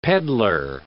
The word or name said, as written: peddler